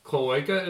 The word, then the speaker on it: cloaca: Okay, cloaca is how you say it? – Yeah.